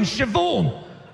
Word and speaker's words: Siobhan: There's no V in Siobhan. Where does the H go then? It goes after the B, obviously. What are you talking about? B-H is pronounced V.